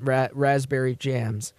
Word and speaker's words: Raspberry: Well, the P is silent. Raspberry Jams.